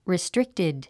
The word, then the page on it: restricted: ristríktid; リィストリクテッド